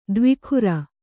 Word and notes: ದೇವಗುರು: kannada

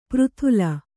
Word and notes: ಪೆರೆದಲೆ: Kannada

